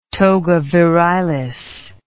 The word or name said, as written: togavirilis